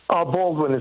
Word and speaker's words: Baldwin: Balmain, New York. Am I saying that right? – Baldwin is fine. – Oh, Baldwin. Okay.